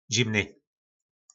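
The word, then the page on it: Zimny: nářečí Po našimu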